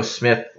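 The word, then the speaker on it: Psmith: In one, Plato Smith, his last name is spelled with a silent P at the beginning.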